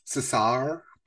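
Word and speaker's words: César: it's pronounced Cesar because it is French.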